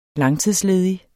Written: langtidsledig

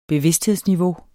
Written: bevidsthedsniveau

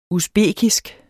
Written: usbekisk

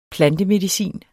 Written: plantemedicin

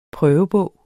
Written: prøvebog

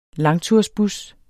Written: langtursbus

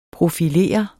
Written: profilere

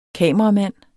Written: kameramand

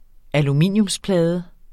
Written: aluminiumsplade

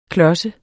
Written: klodset

